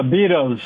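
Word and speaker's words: Beddoes: Yeah, Bedos.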